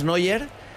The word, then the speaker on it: Neuer: Yo digo, ese se pronuncia, es que es Neuer. Pero se pronuncia casi igual, sí.